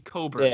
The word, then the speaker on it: Cockburn: I thought you were going to talk about the Cockburn guy. – His name is Kofi Coburn. – Yeah, it's pronounced.